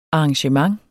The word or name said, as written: arrangement